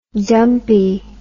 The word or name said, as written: dumpie